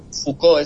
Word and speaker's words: Foucault: Foucault es la pronunciación.